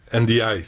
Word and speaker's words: Ndiaye: Là, NDI, c'est comme ça qu'on le prononce, je l'ai appris. – NDI, parfait. NDI.